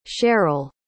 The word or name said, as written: cheryll